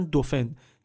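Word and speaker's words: Dauphin: به خاطر همین بهش میگفتن دوفن، که تلفظ فرانسوی دلفین هم هست ظاهرا.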